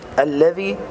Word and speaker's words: الذي: الذي إخراج طرف اللسان في الذال، وهذا خطأ شائع، يقولون الذي، أنطق كل مراعاة الحرف المشدد عند النطق، وأن كل كلها مرققة لكن اللام مشددة، يعني الحرف المشدد ينطق مرتين.